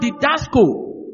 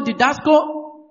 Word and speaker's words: didasko: The word teach is the Greek word didasko. The word teach is the Greek word didasko. Didasko is spelled D-I-D-A-S-K-O. D-I-D-A-S-K-O.